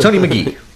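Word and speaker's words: Magee: Tony Maggi, I'm probably mispronouncing his name. – Mr. Lagunitas. – Tony McGee.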